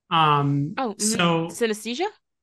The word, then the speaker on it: synesthesia: And I have something called, I can never say the name right, Synthesia. – Synesthesia? – Yes. Okay. I always mix it up.